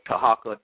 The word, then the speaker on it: Tarhaka: and that's spelled Tahaka, T-A-R-H-A-K-A, Amana, A-M-A-A-N-A, Elbey, E-L and then B-E-Y.